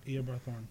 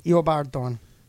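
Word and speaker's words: Eobard Thawne: Eobard Thorne. – I was going to say Edward. Eobard Thorne. Don't argue with me over DC, son.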